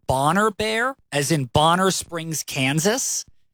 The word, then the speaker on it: Boner Bear: It's spelled B-O-N-E-R-B-E-A-R, but I believe it's pronounced Bonner Bear in honor of Bonner Springs.